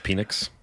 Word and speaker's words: Penix: I believe it's pronounced Penix.